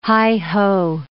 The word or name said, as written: heigh-ho